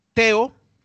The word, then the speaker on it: Tao: Ha ganado un británico, Teo, Tao se escribe, Gogenhan Hart.